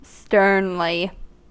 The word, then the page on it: sternly: American English